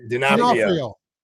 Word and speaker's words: D'Onofrio: And this character is played by Vincent D'Onofrio, if I'm not? – D'Onofrio. – Okay. Never say it correctly.